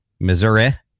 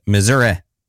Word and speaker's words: Missouri: Missouri, I guess as some people pronounce it. I don't think anyone actually pronounces it that way. But when I went to uh, when in journalism school and Associated Press style guide, that's how they guided us, how they instructed us to pronounce it. Missouri. No one ever says that though.